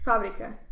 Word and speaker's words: Fábrica: How do you say it? – Fábrica. – Fábrica. Okay, that makes sense.